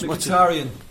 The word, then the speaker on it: Mkhitaryan: One player who I'd love to have had the um, Michael Wacky Wacky Wacky whatever his name was. Mkhitaryan. Mkhitaryan.